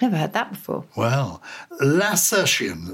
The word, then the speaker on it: lacertian: How do you pronounce it then? – Lacertian, yeah. – Lacertian.